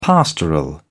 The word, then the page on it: pastoral: British English